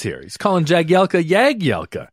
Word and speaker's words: Jagielka: And he goes, it's a properly pronounced Phil Jagielka. – So did you start? – Yeah, silent J. So I always called him Phil Jagielka after that, not Jagielka. – Oh, wow. – I'd always called him Jagielka prior to that, but it's Phil Jagielka.